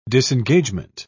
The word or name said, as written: disengagement